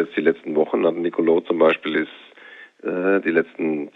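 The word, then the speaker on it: Nicolò: Nicolo, schöne Betonung auf der letzten Silbe gerade von dir übrigens, spielt immer. Spielt immer. – Der Akzent auf der letzten, auf dem O, genau, deswegen ist er lang.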